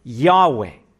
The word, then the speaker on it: Yahweh: The best approximation, according to the best scholarship, is that God's name is Yahweh.